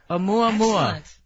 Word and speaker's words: Oumuamua: And this is Oumuamua. – Oumuamua. – Oumuamua.